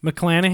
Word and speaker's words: McClanahan: McClanahan.